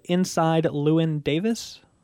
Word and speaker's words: Inside Llewyn Davis: It's called Inside Llewyn Davis.